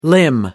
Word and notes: limb: American English

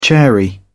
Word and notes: chary: British English

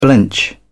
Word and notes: blench: British English

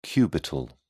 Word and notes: cubital: British English

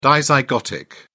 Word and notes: dizygotic: British English

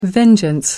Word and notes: vengeance: American English

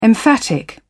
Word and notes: emphatic: British English

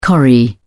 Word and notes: corrie: British English

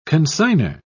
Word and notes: consigner: British English

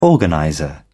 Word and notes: organizer: British English

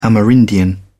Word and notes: amerindian: British English

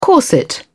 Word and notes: corset: British English

